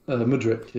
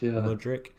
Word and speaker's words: Mudryk: Mudryk, is that how you say it? – Mudrik, Mudryk.